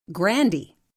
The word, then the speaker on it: Grande: Before her correction came Ariana Grande, who actually says it Grandy.